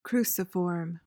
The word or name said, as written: cruciform